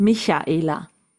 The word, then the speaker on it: Michaela: It's not Michaela. Everyone is saying Mika. It's not with a K. It's Michaela.